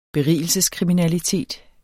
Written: berigelseskriminalitet

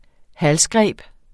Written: halsgreb

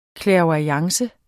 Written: clairvoyance

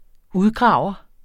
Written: udgraver

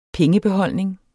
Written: pengebeholdning

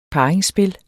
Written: parringsspil

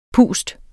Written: pust

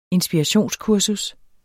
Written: inspirationskursus

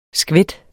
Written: skvæt

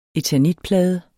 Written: eternitplade

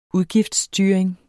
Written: udgiftsstyring

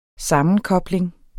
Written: sammenkobling

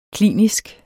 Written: klinisk

